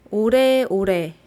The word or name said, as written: oraeorae